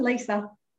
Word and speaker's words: Lisa: Lisa.